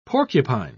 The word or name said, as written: porcupine